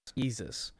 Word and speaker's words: Yeezus: Is it better than how do you say it? Yay or Yeezus? Yeezus.